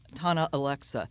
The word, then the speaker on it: Thana Alexa: Am I saying her name correctly? Thana Alexa? – Tana Alexa. Yeah.